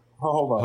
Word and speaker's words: jojoba: Jojoba. – Jojoba. Yep. Yeah. The J is pronounced um, as an um, H.